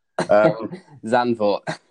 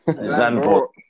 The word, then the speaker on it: Zandvoort: Zandvoort. – Zandvoort.